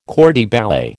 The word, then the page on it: corps de ballet: American English